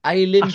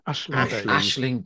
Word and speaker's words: Aisling: Is it Aileen B? – Aisling B.